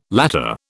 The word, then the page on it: latter: British English